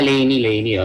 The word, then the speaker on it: LANY: Hindi ko alam kung tama yung pronunciation yung Lainey, L-A-N-Y. – Ah, Lainey, oh.